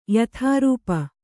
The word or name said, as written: ಯಥಾರೂಪ